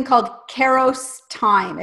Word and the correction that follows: Kairos: Kairos time.